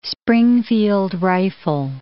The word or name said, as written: Springfield rifle